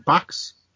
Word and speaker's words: Bachs: Box? – Yeah, I think so. Yeah. B-A-C-H-S. – Box. It's like Bach, but with an X. An S.